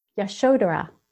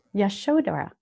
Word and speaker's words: Yashodhara: He said it's actually pronounced, if I can do it, Yashodhara. Yashodhara.